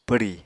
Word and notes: pwri: Apatani

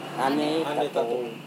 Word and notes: hane-tatowe: Serewen

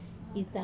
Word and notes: ta: Wamesa

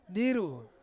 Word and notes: diru: Ansus

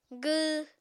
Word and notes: gww: apatani

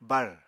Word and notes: bár: apatani

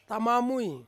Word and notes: tamamui: Ansus

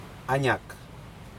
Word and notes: aNak: Aneityum

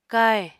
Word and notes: káhè: Apatani

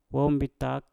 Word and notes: wom bitak: mpur

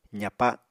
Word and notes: zapa: apatani

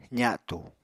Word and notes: za7tu: apatani

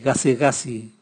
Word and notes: gasigasi: Raga

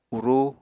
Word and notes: urú: Apatani

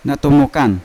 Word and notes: natomokan: Yawa

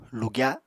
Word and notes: lugya: Apatani

